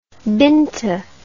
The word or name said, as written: beento